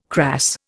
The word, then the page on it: crass: Indian